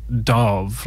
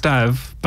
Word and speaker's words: Dav: the Americans just kept on saying Dav, like Dove, and I was just like, no, it's Dav.